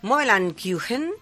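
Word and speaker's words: Möbel und Küchen: Dime, no, pues dime cómo se pronuncia. – Ankyuchen. – Ankyuchen, vale, pues Ankyuchen. Mobile Ankyuchen, que significa muebles y cocinas en alemán.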